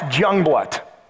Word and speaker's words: Jungeblut: It's pronounced Jungblut. It's spelled J-U-N-G-E-B-L-U-T.